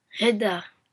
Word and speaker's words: Kushida: Kushida.